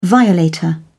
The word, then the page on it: violator: British English